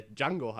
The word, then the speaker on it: Django: ähm, äh, wo er quasi mit dem redet und ihm erklärt, dass er Django heißt, mit dem D und dass das D äh, stumm ausgesprochen wird.